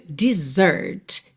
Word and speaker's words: desert: Aqui nós temos o verbo to desert. Se escreve D-E-S-E-R-T. Alright? D-E-S-E-R-T. A sílaba tônica é a segunda, então desert, desert. E esse S tem som de Z, right? Desert.